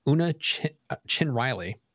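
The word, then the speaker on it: Una Chin-Riley: Una Chin Riley, did I say that right? Mm-hmm. Referred to as Number One, goes missing during a first contact mission.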